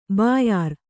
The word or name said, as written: ಬ್ಯಾರ